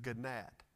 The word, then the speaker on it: gnat: According to Matthew 23 24, Jesus says that some strain at a gnat. I don't know why they put the G there. It's silent, it's a gnat.